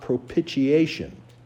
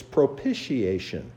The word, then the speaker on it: propitiation: And she said, "Son, it is not propitiation. You don't pronounce the word that way. It's propitiation. It's a soft T sound."